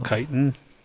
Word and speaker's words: chitin: Is it chitin or chitin? – Hell, chitin. – Chitin. – Okay, it is chitin. Okay. Similar to previous studies on mice, the researchers found that eating a chitin seems to promote a healthy microbiome in the lower gastrointestinal tract.